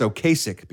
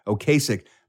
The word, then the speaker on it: Ocasek: A lot of people think it's Ocasek because it's spelled O-C-A-S-E-K. – Right. – The case is right in the middle of it. Ocasek, but it's actually Ocasek.